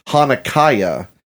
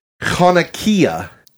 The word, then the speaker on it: Hanukkah: I pronounced the large version of the menorah Hanukkah, and that is incorrect. Lisa told me that the correct pronunciation is Hanukkah, not Hanukkah. – So, and she even told me that like throat clearing noise.